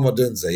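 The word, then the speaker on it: Odunze: I thought, is it Odunza? How do you pronounce it? Yeah, Rom Odunza, yeah.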